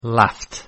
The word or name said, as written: laughed